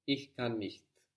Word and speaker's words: Ich kann nicht: Ich kann nicht. – Good. And the pronunciation was better this time. No? Less ch, because you could think just about that bit. Ich kann nicht.